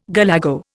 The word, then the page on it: galago: Indian